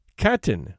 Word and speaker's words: Katyn: Katyn, which is pronounced as written, is a town about 15 miles west of Smolensk.